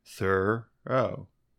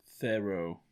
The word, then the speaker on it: Thoreau: Yeah, like Thoreau. I do. Thoreau. – I like that one too, actually. Thoreau. – With the H sounded and stressed on the first syllable.